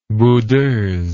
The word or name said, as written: boudeuse